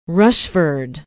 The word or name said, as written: Rushford